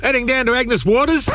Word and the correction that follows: Agnes Water: Mate, it's pronounced Agnes Water.